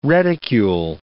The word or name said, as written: reticule